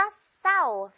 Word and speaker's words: South: S 하면서 South. 마지막에는 여러분 혀를 꼭 내밀어줘야 돼요. 메이롱으로 끝내줍니다. South. South. 실수하기 좋은 발음이에요. South라고 할 수가 있다 보니까 사우스가 아니라 사우스가 되겠죠. TH 살려서.